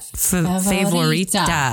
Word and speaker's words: Favorita: Yes. It's like favorite. Favorita. How would you say that? – Favorita.